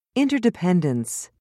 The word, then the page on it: interdependence: British English